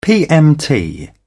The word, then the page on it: pmt: British English